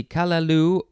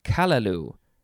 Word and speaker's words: callaloo: Let's see, kalaloo, or you could emphasize the first syllable, which would be kalaloo.